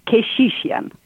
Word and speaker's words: Keshishian: Yeah, it's Keshishian. – Keshishian. So Vicky? – Yeah, it's Armenian last name. – Okay, Vicky Keshishian. Nice. Okay, because I thank you for sending it phonetically for me. I love phonetic spelling, and I think I did pretty good, but I was going to say Keshishian, but it's Keshishian. – Nice. That's correct.